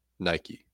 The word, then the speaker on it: Nike: Not a short E, a silent E. Nike. Long E.